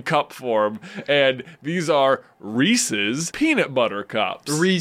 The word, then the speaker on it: Reese's: Everyone says Reese's because it rhymes with PCs. And PCs isn't a word.